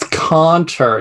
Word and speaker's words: cantor: Well, it's cantor. You have to use the German pronunciation.